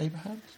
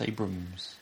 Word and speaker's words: Abrams: Abrams, isn't it? – Abrams. Was it Abrahams? No, it's Abrams.